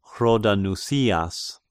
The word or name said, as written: Hrodanousias